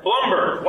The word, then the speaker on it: Blumberg: Arnold, I know I mispronounced your last name, so could you please say it correctly? – Blumberg. – Blumberg. Why is that hard for me to pronounce? – I don't know why people can't figure that out when there's no E in it. The first part. – No, it's because of Bloomberg.